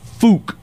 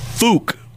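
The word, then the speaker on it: Phuc: which I'm going to say if you were in Vietnam and somebody had that that name, it might sound something like Fook.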